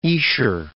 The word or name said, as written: Esher